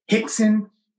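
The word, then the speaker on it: Rickson: Then I started formally with my teacher, Ralph Gracie, but it's pronounced health in Portuguese, Brazilian Portuguese. All the R's are H's. Health, Royce, Hickson.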